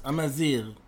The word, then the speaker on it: Amazigh: Like, why is she wearing these things that belong to, they reflect this fashion of, so you know how to pronounce this, Amazigh? – Yeah, that works. Amazigh.